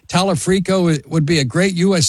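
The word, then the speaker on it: Talarico: If you and I sit here on November the 4th and 5th and say, okay, we got Dr. Abdul, we have Tallarico, we have Angie Nixon, do you and I not perceive reality? Is that possible? – I think you're mispronouncing his name. It's Talafrico.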